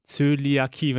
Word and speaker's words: Zöliakie: Das nennt sich Zöliakie, wenn ich das richtig ausgesprochen habe.